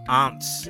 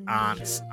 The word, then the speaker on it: aunt: It's aunt, not aunt. – A-U-N-T.